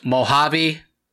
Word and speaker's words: Mojave: And at this point, Robot Paul, with relief, says, finally, and goes on to tell Mr. Braille, it's pronounced Mojave. H, there's an H sound.